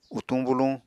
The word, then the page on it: utuñ buluñ: Apatani